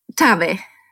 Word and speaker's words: Tave: Tave. – Okay, got it. – Yeah, Tave, I think it's called in States the same. – Yeah, Tave. I have no idea either. It's spelled T-A-V-E.